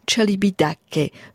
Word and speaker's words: Celibidache: » Juste, quant à Celibidache, la prononciation qui convient est « Sergeiu Celibidache », donc un tout petit peu à l'italienne.